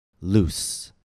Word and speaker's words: loose: Please listen and repeat the following words in which SE after a vowel is pronounced S. House. Mouse. Promise. Use. U-S-E as a noun. Use. Used to. Loose. L-O-O-S-E.